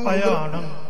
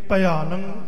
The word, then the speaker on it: पयाणं: पयाणं ए पयाणं इंदा उच्चारण होवेगा जी पब्बा ईड़ी नु सिहारी आड़े नु कन्ना ते नाणे नु टिप्पी जी हां जी